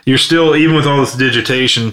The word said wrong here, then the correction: digitization: Did I say that right? – Digitization? Yeah. I think that's it.